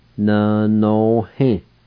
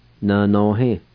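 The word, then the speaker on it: nvnohi: N no he. N no he. N no he.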